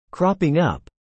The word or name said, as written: cropping up